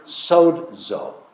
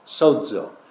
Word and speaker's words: sozo: In the Greek of the New Testament, it's the word sozo, sozo. S-O-Z-O is how it's spelled. It's pronounced S-O-D-Z-O, sozo.